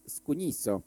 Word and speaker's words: scugnizzo: El más grande, Scugnizzo, se pronuncia Scugnizzo en dialecto napolitano.